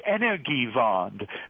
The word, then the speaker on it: Energiewende: this Energiewende, that's how it's pronounced.